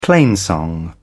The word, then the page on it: plainsong: British English